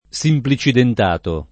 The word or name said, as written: simplicidentato